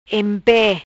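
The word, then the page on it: Empe: Patua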